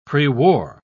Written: prewar

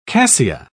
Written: cassia

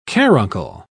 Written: caruncle

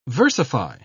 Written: versify

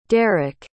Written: derreck

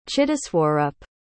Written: chittaswarup

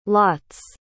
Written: lots

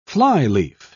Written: flyleaf

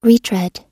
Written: retread

